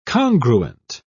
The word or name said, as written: congruent